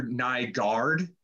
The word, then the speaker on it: Nygaard: So Nygaard, Nygaard. When people hear Nygaard, they spell it G-A-U or, you know, guard.